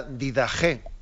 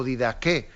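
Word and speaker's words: Didaché: que es la llamada Didaje, eh, o Didaque, eh, o Didache, porque aquí también se le dice de distintas formas, ¿no? se pronuncia este nombre.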